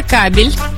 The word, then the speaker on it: кабель: Так, это кабель. – Кабель? – Угу. – Ударение на А.